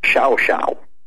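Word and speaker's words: Llao Llao: They had a fire, rebuilt in 1939, and it's spelled LLAO-LLAO. But in Argentine Spanish, which is different from everybody else's Spanish, it's pronounced xiao xiao.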